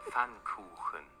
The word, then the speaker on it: Pfannkuchen: Let's say it all together. Pfannkuchen. – Kuchen, I was right. – Pfannkuchen. – Pfannkuchen. – Now, let's split the word into syllables and then pronounce each syllable one by one.